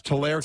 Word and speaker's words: Tulare: Is it Tulare or Tulare, California? – Oh, there it is. – Tulare, California. You see that? – I do. – Tulare. It's Tulare, California.